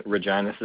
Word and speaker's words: Regina: I am in Regina, Saskatchewan.